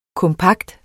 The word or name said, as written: kompakt